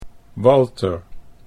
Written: Walter

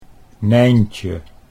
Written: Nijntje

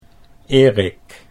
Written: Erik

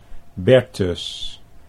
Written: Bertus

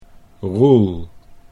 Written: Roel

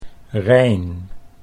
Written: Rein

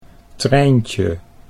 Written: Trijntje

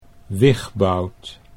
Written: Wigbout

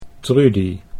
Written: Trudy

